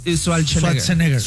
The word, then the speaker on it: Schwarzenegger: A ver, pronuncien su apellido. – ¿Cómo? – Schwarzenegger. Muy bien.